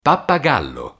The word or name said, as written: pappagallo